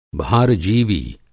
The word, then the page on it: भारजीवी: Hindi